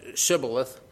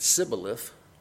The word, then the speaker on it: Shibboleth: For example, in Judges 12, verses 5 and 6, they identified Ephraimites because they couldn't pronounce Shibboleth. They pronounced it Sibboleth, and that's how they determined them and killed them during a war.